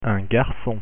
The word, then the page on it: garcon: French